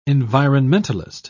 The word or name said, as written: environmentalist